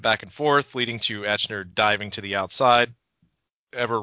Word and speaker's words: Aichner: Marcel Barthol and Fabian Aichner? I always pronounce his last name wrong. – Aichner! – He's German.